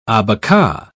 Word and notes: abaca: British English

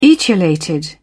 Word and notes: etiolated: British English

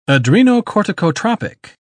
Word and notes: adrenocorticotropic: British English

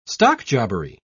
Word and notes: stockjobbery: British English

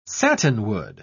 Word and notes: satinwood: British English

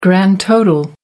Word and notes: grand total: American English

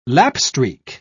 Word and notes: lapstreak: British English

